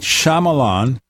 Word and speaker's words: Shyamalan: Night Shyamalan.